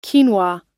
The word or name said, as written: quinoa